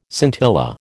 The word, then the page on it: scintilla: American English